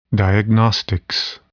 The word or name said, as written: diagnostics